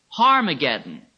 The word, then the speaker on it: Armageddon: Actually, in Hebrew, it would be pronounced Harmageddon. In Hebrew, it would be pronounced H, Har. And it means Mount Megiddo.